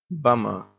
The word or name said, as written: bummer